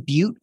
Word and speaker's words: Beute: Again, my name is Ethan Butte. Last name is spelled B-E-U-T-E. So hit me up on LinkedIn or really on any social network. I'm Ethan Butte, either two words or jammed together on pretty much all the social networks.